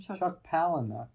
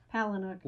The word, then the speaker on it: Palahniuk: Chuck Palahniuk. – Palahniuk.